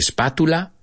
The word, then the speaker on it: spatula: I think you say a spatula. We pronounce it the T-U like a C-H.